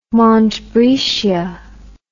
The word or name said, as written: montbretia